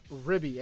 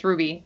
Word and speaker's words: RWBY: I think, um, I think it's pronounced Ribby. Everyone says it's Ribby. I'm just like, okay. – It's a W. I'm gonna say yeah. it like how a lot of my friends who are fans and cosplay it, they say it's Ruby. I'm gonna say it's Ruby.